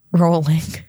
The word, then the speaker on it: Rowling: We've been saying Rowling's name wrong. – Rowling. It's Rowling.